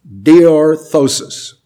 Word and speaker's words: Diorthosis: and I'll spell it with English letters. D-I-O-R-T-H-O-S-I-S. Diorthosis.